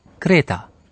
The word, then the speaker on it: Kreta: إنها تنطق بالألمانية كريتا.